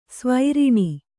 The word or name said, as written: ಸ್ವೈರಿಣಿ